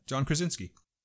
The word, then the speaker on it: John Krasinski: It's called, his name is John Krasinski. Is it John Krasinski? – Yeah. You're talking about Jim from The Office. John, no, you said it correctly. John Krasinski.